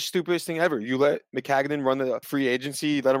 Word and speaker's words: Maccagnan: Every everybody pretty much uh, um, Mechanic um, Hanahan, where the fuck his name is, I can never pronounce his last Mc- name. Maccagnan. – Maccagnan, there it is.